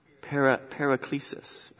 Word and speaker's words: paraklesis: Paraklesis, right? Pastor Dom, You should understand this. Yeah. It sounds like the word paraclete, right?